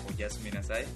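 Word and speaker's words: Oyasumi Nasai (おやすみなさい): Era Oyasumi Nasai.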